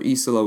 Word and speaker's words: Isola: The idea for Isola was something that, is that the right way to say it? Am I pronouncing it right? – Mm-hmm, yeah, it's perfect.